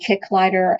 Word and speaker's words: Kicklighter: Again, my name is Cindy Kicklighter, and my last name is spelled K-I-C-K-L-I-G-H-T-E-R.